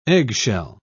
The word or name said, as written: eggshell